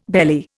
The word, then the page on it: bailee: Indian